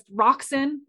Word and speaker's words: Roxan: And then my name is spelled R-O-X-A-N. Nothing else, just Roxin. It sounds like a chemical, and then McDonald, like the restaurants, MC.